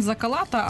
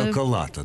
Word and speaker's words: Закалата: Я коли почав дивитися, хто ж автор текстів, я побачив, я можу зараз переплутати прізвище, неправильно вимовити, Єза Лата, та? Чи Єза Тала? – Євген Закалата, але... – Закалата, та? – Так, це от.